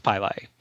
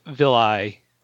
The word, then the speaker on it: pili: Pronounced pili. Pili. I wanted to say villi. Or pili, because I'm used to villi in biology.